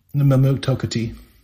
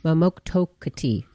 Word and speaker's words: Mamotokuti: Mamotuki? – Mamotokuti. – Mamotokuti. Oh, I missed something in there.